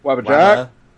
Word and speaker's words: Wabbajack: Wabbajack. – Wabbajack. – Wabbajack. Wabbajack. – Guys. Do it slowly in two syllables. Wabba.